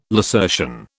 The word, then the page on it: lacertian: British English